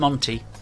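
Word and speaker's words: Monti: Now, I can't pronounce Monty. – Yes, Monty. Shardash. – Shardash.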